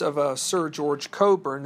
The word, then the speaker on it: Cockburn: Now, if you pronounce his name, it's spelled C O C K B U R N Cockburn, but to the British, it is Coburn.